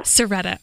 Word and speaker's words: Cerreta: Serretta.